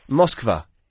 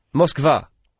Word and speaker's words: Moskva: Moskva? – Moskva. – Yep. We should pronounce place names the way they're pronounced in the place, surely.